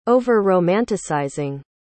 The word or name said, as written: over-romanticizing